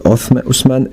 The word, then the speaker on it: Osman: Othman Ertuğrul. Bon, je prononce mal, note de traduction, c'est Osman Ertuğrul.